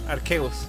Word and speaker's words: Arceus: o Arceus o Arceus. Arceus. Sí, caballero, sí, así se pronuncia.